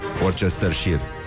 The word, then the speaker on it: Worcestershire: dar nimeni nu știe să pronunțe Worcestershire. Worcestershire. Da, Worcestershire. – Worcestershire.